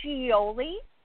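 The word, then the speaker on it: Shioli: So it's Scioli. Yes. – Scioli.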